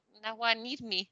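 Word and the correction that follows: Najwa Nimri: Naya Nimri. – Naya. – Naya Nimri.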